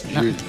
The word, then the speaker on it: Llull: Bueno, y yo lo entiendo, porque pronunciar Llull, si no eres valenciano, balear, catalán, no se te da bien. – Difícil. – A ver, José, di Ramón Llull. – Ramón Llull. – ¿Ves? Llull.